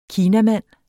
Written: kinamand

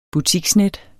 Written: butiksnet

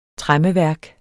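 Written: TREMMEVÆRKERNES

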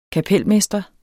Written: kapelmester

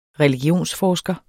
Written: religionsforsker

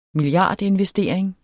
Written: milliardinvestering

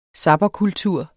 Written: zapperkultur

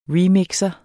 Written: remixer

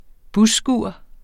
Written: busskur

